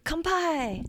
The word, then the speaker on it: Kanpai: It kind of rhymes with that. – What was it? I can't remember. – Kanpai!